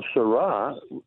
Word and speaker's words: Sarah: Well, Carlos Sarah, it rhymes with hurrah, S-A-R-A-H. He's got a book called God or Nothing, which is a tremendous book.